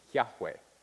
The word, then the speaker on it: Yahweh: And it seems from the people who speak Hebrew naturally, normally, who know how these things work, that that's how the word should be pronounced. Yahweh.